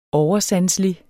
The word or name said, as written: oversanselig